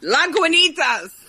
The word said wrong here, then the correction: Lagunitas: Okay. Lagunitas, Lagunitas.